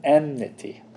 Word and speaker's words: enmity: Am I saying that right? I can't say it right now. Hostility and enmity. That's right, enmity. Enmity.